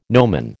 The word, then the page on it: nomen: American English